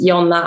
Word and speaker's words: Jonna: how do you pronounce your name? – For me, if I try to say my name to someone, I say Jonna Andersson, or more in Swedish, it's Jonna Andersson.